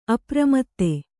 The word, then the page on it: ಅಪ್ರಮತ್ತೆ: apramatte